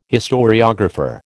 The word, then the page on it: historiographer: American English